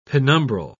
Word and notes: penumbral: British English

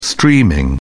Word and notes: streaming: British English

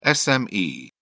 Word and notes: SME: British English

